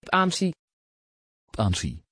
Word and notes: pansy: Dutch

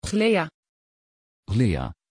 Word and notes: chléa: Dutch

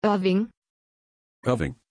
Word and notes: irving: German

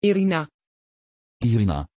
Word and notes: irina: Dutch

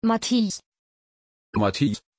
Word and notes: Matthijs: German

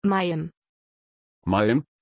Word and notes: Mayim: German

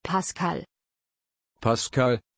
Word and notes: paskal: German

